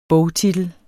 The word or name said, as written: bogtitel